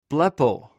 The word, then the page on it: blepw: greek